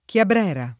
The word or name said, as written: Chiabrera